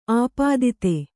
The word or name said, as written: ಆಪಾದಿತೆ